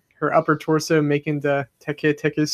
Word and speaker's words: Teke Teke: All right, is it Tiki Tiki? Is that how you say it? Or is that completely wrong? – Teke Teke. Teke Teke. Okay, I actually first heard of this on Sir Spooks, his video.